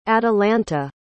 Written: atalanta

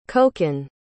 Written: kokan

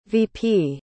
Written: veepee

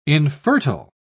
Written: infertile

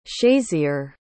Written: shayzier

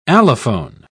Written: allophone

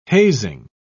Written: hazing

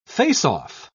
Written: face-off

